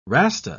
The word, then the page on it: rasta: British English